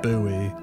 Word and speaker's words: buoy: except it doesn't actually translate in America because they pronounce it buoy.